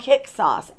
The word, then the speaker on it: Hyksos: They were called Hyksos, H-Y-K-S-O-S, just so you know how it's pronounced.